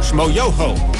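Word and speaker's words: Schmoyoho: Shmo Yoho, accent on the yo.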